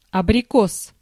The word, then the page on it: albicocca: /al.biˈkok.ka/